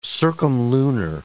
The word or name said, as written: circumlunar